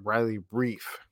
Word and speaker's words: Riley Reiff: I've been contacted that I've been saying it wrong all along, but Riley Reiff.